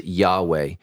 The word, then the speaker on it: Yahweh: We're talking about the name, we would pronounce it Yahweh.